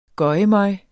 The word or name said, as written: gøgemøg